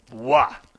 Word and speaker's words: Roy: I don't know how you get, however you pronounce his name, it's spelled R-O-Y. That looks like Roy to me. What do I know? – No, I know. Wah. Patrick Wah.